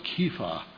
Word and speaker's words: Kepha: You shall be called Cephas," or Kepha, as it would have been in Aramaic or Hebrew, which is translated a stone. And Kepha and Peter mean the same thing.